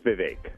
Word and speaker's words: Vivek: Is it Vivek? Did I get that right? – You're close. It's Vivek. Like it rhymes with lake. – Vivek.